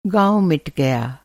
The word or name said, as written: मिटना